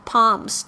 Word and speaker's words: palm: press your palms，这个 palms，P A L M，但是这个 L 没有发音呢，啊，这个 palm。Together near your chest。chest，C H E S T，就是胸口的意思。